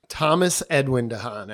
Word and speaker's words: Thomas Edwin Dehaan: Thomas Edwin Dehaan, actually.